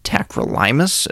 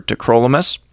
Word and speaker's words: tacrolimus: I have heard it pronounced tacrolimus as well. I prefer tacrolimus.